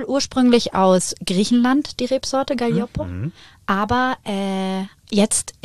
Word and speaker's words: Gaglioppo: Und das ist auch wieder so ein Zungenbrecher zum Aussprechen, weil schreibt man ja Gaglioppo. – Ja, das stimmt. – Gaglioppo gesprochen. – Gaglioppo.